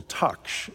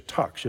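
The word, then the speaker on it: taqsh: And the growth on a fig tree is called a taksh. It's spelled T-A-Q-S-H, a taksh.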